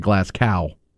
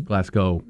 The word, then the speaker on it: Glasgow: And both those presidents got it wrong. They call it Glasgow. It's not Glasgow. – No. – Glasgow.